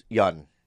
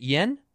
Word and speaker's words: Yeun: That's not why I called How do you say his last name? – Yun. – Because you corrected yourself. Is it Yen? – Yun. – Yun. – Yeah. – Y-E-U-N.